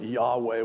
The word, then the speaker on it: Yahweh: And of course, partly why that had such power is in the Old Testament, the personal name for God, which we pronounce it Yahweh. We don't know what the vowel markings were because they didn't use vowel markers on it. But it's YHWH.